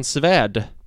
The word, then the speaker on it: Svärd: Sebastian Svärd. Jag har glömt vem. – Danska, har spelat i syrianska. – Oj då. Ja. – Men också svärd med svenskt ä, vilket tyder på att han har svenska rötter.